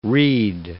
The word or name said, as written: Reade